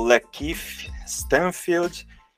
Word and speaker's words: Lakeith Stanfield: Você, não temos aqui o nosso querido Márcio Melo, mas é o Lakeith Stanfield, e por último, não menos importante, que é a Vanessa, né, a Van, interpretada por Zazie Bates.